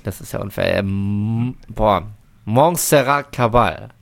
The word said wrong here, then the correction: Caballé: Nein. – Caballé. – Caballé. Bitte nochmal als Ganzes zum Reinschneiden. – Montserrat Caballé.